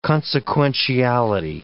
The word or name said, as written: consequentiality